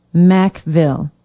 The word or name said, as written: mackville